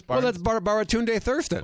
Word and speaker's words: Baratunde Thurston: Baratunde Thurston. – Baratunde Thurston sits with the biggest artists and influencers of our time, including John Legend, Wyclef Jean, Pete Wentz, Vanessa Grimaldi.